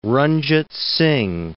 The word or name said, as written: Ranjit Singh